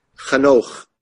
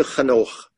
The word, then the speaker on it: Hanoch: Hanukkah. In English, it's spelled H-A-N-O-C-H. You've seen it in translation, mistranslated as Enoch. Let's get back to Hanukkah.